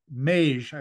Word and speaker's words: Mage: Then you come to Mage, if you say that correctly. – Yeah, that's it.